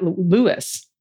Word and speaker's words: Lewis: Their ragdoll cat, Lewis, pronounced like the Sun King he is, loved it, and we ended up having to move his tower, give him a better viewing angle.